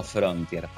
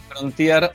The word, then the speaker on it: Frontier: Podés decirle Nissan Frontier o Nissan Frontier. Eh, eh, yo creo que la primera pronunciación fue la más correcta si salís de Argentina. Este, a cualquier otro mercado y la otra es para nosotros.